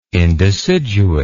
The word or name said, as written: indeciduate